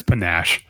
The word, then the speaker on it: panache: I believe it's pronounced panache. – Panache? – No, it's panache. – Oh, okay. Panache.